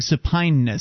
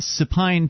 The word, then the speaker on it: supineness: Nick, you're mispronouncing supineness. Supine, to lay on one's back, essentially to lay down.